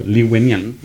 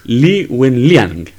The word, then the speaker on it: Li Wenliang: Eh, por otra parte, tenemos el punto de el doctor Lin Liang, no sé cómo se pronuncia, pero Li Wenliang, perdón, Li Wenliang, así.